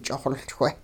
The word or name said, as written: ch'ixoLchwe